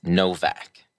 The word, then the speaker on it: Nowak: And so the W actually makes that sound, and so a lot of people mistake it for Nowak, and I always sort of give a little lesson on the Polish alphabet and that to my students, because I really, it's something that's important to me, the pronunciation of names, and I make sure to try and pronounce my students' names right. So yes, it's Novak.